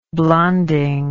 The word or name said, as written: blonding